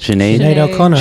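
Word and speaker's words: Sinéad O'Connor: Sinead O'Connor.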